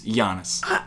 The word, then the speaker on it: Giannis: I could never bring myself to look at you in the eyes as a grown man and say, Danny, it's not Giannis, it's Giannis. So, sorry on Danny's behalf, he's been saying it wrong. Danny, I know you're going to own up to it. It is Giannis.